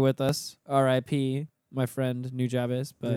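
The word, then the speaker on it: Nujabes: I don't know how to pronounce it. – New Jabez, yes.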